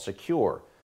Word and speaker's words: Sekur: So I want to introduce you to a new privacy and cybersecurity application called Secure. It's spelled S-E-K-U-R.